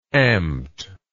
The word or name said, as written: amt